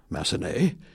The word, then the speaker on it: Massenet: The correction, I know the S is silent in Jules Massenet, and the addition will be, and neglected to mention so far, accompanist Matt Horton for Shohan Ohanian today.